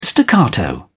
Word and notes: staccato: British English

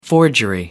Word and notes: forgery: American English

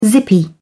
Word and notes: zippy: British English

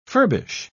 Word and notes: furbish: British English

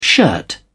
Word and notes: shirt: British English

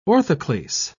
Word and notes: orthoclase: British English

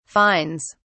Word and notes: fines: British English

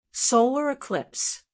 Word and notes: solar eclipse: American English

